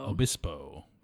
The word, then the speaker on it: Obispo: Obispo.